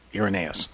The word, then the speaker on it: Irenaeus: Irenaeus.